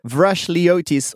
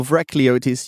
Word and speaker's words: Vrachliotis: Vrakliotis or Vrakliotis. You can choose. And you picked the second and that was pretty good.